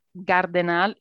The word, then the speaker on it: Gardenal: And for the English speakers, Gardenal is like garden and A-L at the end. Just wanted to make the point, because otherwise I know with my pronunciation of my surname, English speakers would struggle normally.